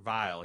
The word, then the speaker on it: Vile: I really like Jessica Lange's version of Kurt Wheels, I think. Or no, Kurt Vile.